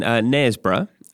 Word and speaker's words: Knaresborough: You lived in Knaresborough. Have I pronounced that right? – Yeah, perfect. Yeah.